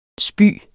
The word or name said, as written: spy